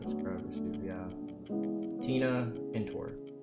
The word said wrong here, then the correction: Tina Tintor: Tina Tintor. That's her name.